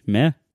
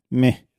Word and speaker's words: Meh: Am I pronouncing that right? M-E-H? Meh? – Meh. – I've never actually said this word. – You have to sound more apathetic.